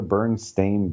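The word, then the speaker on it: Berenstain: Steen, not stain. – Yeah, see, they are the Bernstein Bears.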